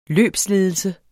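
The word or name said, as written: LØBSLEDELSE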